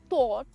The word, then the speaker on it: thot: Now, what it actually means, T-H-O-T, thought, that's how it's pronounced.